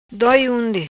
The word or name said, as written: Doi Unde